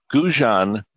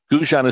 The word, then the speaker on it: Gougeon: Look up 1937 Guggen Streamliner. Guggen is spelled G-O-U-G-E-O-N Streamliner. – Say it one more time, and then we got to go. Say it one more time. – 1937, Guggen Streamliner.